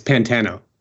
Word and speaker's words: Pantano: Am I saying your last name right, Steve? How do you say your last name? It's Pantano. – Pantano.